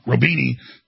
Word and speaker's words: Roubini: One of the top economists out there, Nouriel Roubini, I had him on years ago, I always say his name wrong, Roubini, says World War III has already effectively begun.